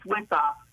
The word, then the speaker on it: Wisoff: Right, first of all, it's Wisoff. – Wisoff. – Right. – Okay, thank you. – No one pronounces it right. I had a teacher in school that called me Wisoff.